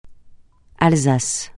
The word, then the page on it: Alsace: francais